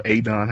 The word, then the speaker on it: Adon: And, well, you got a Don, or Adon, however you pronounce his name.